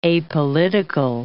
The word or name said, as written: apolitical